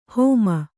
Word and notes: ಹೋಮ: hōma

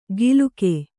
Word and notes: ಗಿಲುಕೆ: giluke

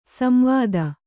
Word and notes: ಸಂವಾದ: samvāda

